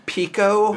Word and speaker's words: PCO: This it's pronounced Pico and Scarl.